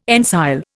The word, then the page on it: ensile: Indian